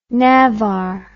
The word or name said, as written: navar